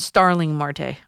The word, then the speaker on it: Starling Marte: They don't want Sterling Marte. They want Starling Marte. Okay, sorry about that. Pronounced it wrong.